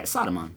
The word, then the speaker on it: Sadiman: S-A-D-I-M-A-N? – Yeah, Sadamon.